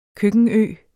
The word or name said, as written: køkkenø